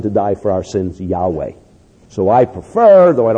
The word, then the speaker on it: Yahweh: what the pronunciation would have been, was Yahweh.